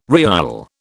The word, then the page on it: riyal: British English